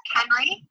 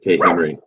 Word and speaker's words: Khenri: They can find me on Facebook at Kenry, which is K-H-E-N-R-I. – You know what? I never knew you were supposed to pronounce it as one thing. I always said Kate Henry. – Oh, yeah? – Now I feel dumb. Well, learn. I can't hear the H is silent.